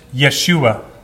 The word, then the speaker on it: Yeshua: Salvation, if you remember, in the Hebrew language is pronounced Yeshua, Jesus.